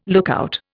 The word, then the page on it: lookout: Indian